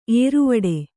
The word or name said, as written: ಏರುವಡೆ